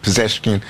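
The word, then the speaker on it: Pezeshkian: Yeah, so I think that what we saw from Mr. Pezeshkin, did I pronounce that correctly? – Pezeshkin.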